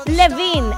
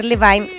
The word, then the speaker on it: Levine: Adam Levine. Alguien me corrigió en las redes sociales. Me dijeron, no se dice Levine. La gente comete el error de decir Levine.